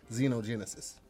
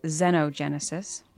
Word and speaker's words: xenogenesis: Xenogenesis. – Well, online says Xenogenesis, but that seems like a British pronunciation, and we're in America. I would have said Xenogenesis as well. – Okay. – Like xenophobia.